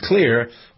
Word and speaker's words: Xlear: And then, using Navage, get the stuff out of my nose, and then keeping it clean with Clear. That's a prescription for fabulosity today. It really is. Clear, X-L-E-A-R, is pronounced clear.